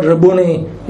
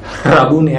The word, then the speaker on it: Rabboni: The only other place where this word Rabuni is used, that's how it's pronounced. Rabuni, actually. Rabuni. There's a H there.